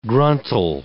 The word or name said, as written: gruntle